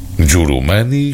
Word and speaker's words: Jurumani: Jurumani, explica João Freire no excelente libreto que acompanha o disco, é o modo como o nome germano é pronunciado pelas populações rurais da Guiné-Bissau.